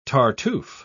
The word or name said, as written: tartuffe